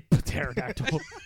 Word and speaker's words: pterodactyl: Why do you say pterodactyl? The P is silent, dude.